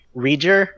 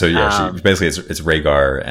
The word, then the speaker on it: Rhaegar: She called him a Reger or something like that.